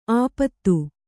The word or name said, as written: ಆಪತ್ತು